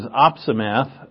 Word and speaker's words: opsimath: The word of the day is opsimath. That's how to pronounce it as well. Opsimath, for those who do not video, it is O-P-S-I, math, M-A-T-H.